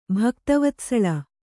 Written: ಭಕ್ತವತ್ಸಳೆ

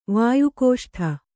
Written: ವಾಯುಕೋಷ್ಠ

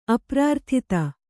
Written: ಅಪ್ರಾರ್ಥಿತ

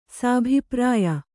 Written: ಸಾಭಿಪ್ರಾಯ

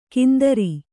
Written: ಕಿಂದರಿ

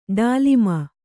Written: ಡಾಲಿಮ

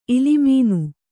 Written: ಇಲಿಮೀನು

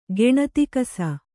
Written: ಗೆಣತಿಕಸ

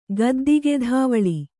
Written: ಗದ್ದಿಗೆಧಾವಳಿ

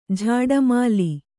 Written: ಝಾಡುಮಾಲಿ

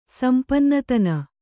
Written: ಸಂಪನ್ನತನ

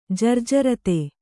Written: ಜರ್ಜರತೆ

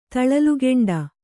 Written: ತಳಲುಗೆಂಡ

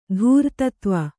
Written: ಧೂರ್ತತ್ವ

